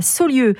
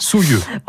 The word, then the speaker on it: Saulieu: Oui, Saulieu. Quand bourguignon morvandio, on prononce Saulieu. Alors, je ne suis pas sûre que je prononce très bien. – D'accord. – C'est ce qu'on m'a dit, mais voilà. – Saulieu.